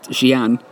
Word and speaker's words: Xi'an: Xi'an, spelled X-I-apostrophe-A-N, but pronounced Xi'an, is a fascinating place.